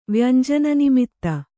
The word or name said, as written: ವ್ಯಂಜನನಿಮಿತ್ತ